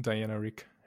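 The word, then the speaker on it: Diana Rigg: Wie heißt sie richtig? Bitte korrigiere äh, mich nochmal. Dame Diana Rick.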